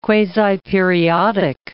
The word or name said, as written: quasiperiodic